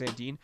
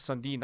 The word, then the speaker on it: Sandin: I like what he was doing, but I think that opportunity to bring in a guy like Rasmus Sandin, who I'll admit firsthand, I know nothing about him. When I saw the trade, I was like, I didn't even get his name right. I thought it was Sundin.